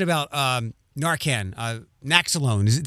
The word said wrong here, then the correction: Naloxone: Did I say that right? – Naloxone, you were close. – Naloxone, yeah, I transpose the letters.